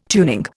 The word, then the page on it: tuning: Indian